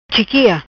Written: Chiquia

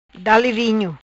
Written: Dale vinho

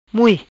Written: Mui

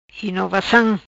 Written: Inovacam

